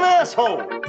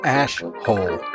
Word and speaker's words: Ash Holes: Pardon my French, but you're an asshole. – It's pronounced Ashhole.